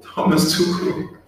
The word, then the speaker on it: Thomas Tuchel: Thomas Tuchel.